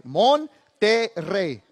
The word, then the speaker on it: Monterrey: Monterrey.